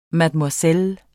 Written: mademoiselle

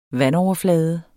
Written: vandoverflade

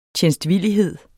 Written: tjenstvillighed

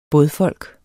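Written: BÅDFOLK

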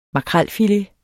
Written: makrelfilet

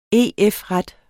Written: EF-ret

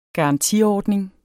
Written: garantiordning

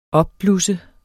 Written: opblusse

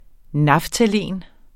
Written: naftalin

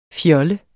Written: fjollet

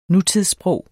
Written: nutidssprog

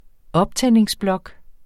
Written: optændingsblok

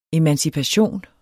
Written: emancipation

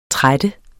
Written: trættes

